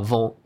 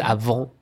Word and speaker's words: avant: so you don't actually pronounce the T. So it's mm-hmm. avant. It's not avant. Mm-hmm. It's avant and then avant garde.